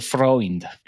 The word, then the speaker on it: Freund: ¿cómo se pronuncia tu apellido? Porque no me suena muy, muy castellano, ¿no? Y me decía, se dice Freund. ¿Lo estoy diciendo bien? Así es, Freund me estabas explicando sus raíces.